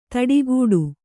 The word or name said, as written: ಥಟ್ಟುಗೆಡು